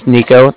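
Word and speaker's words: Niko: Again, my name's Nico. That's N-I-K-O.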